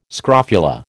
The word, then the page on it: scrofula: American English